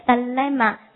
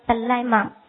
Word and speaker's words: dilemma: 你看它的发音也很有感觉，dilemma，达赖喇嘛，dilemma，对不对？